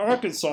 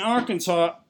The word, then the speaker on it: Arkansas: We're all the way up to Arkansas already. – Wow. – And in Arkansas, I kind of knew about this law. You must pronounce the state name correctly. You cannot pronounce it Arkansas. It's Arkansas.